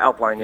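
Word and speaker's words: Elflein: How do you pronounce his name? Elfline? – Yeah, Elfline, yeah.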